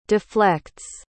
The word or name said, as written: deflects